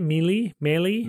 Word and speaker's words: Melee: Melee.